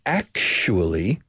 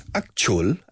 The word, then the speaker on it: actually: Aunque los británicos dicen actually, mucha che en el inglés británico con esta palabra. Actually, in English, in American English, es como zapato, shoe, a shoe.